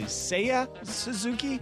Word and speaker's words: Seiya Suzuki: Now, am I saying it correctly? Seiya Suzuki? – That is correct.